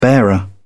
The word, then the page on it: bearer: British English